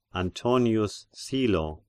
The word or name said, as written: Antonius Silo